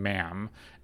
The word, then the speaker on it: ma'am: that it's just a very similar vowel for a lot of people in the UK when pronouncing mum for your mom and also pronouncing ma'am, MA'AM.